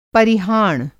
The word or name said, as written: परिहाण